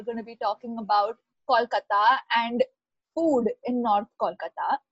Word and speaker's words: Kolkata: It's Kolkata. You got to say Kolkata like a true bong. You're going to have to like make really, you have to like accentuate your O's. Come on, Kolkata. – Kolkata. – Yes. Okay. See the A, the Ko and the A, Kolkata. That's how you say it.